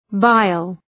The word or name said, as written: bile